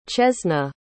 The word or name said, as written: chesna